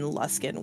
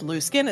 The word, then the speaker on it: Luskan: which I discovered recently that I've been saying the name of the town wrong, and it's not pronounced Luskin, it's pronounced Luskin. – How dare. – Well, I mean, in my defense, I didn't know how it's pronounced, but now I do, so I'm going to pronounce it Luskin.